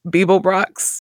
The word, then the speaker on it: Beeblebrox: Beeblebrox.